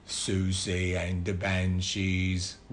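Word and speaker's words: Siouxsie: How do you say that? Susu? – No, Susie. – Susie? – Yeah. But, you know, for those who are not, it's spelled Sioux something. – S-I-E.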